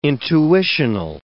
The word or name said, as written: intuitional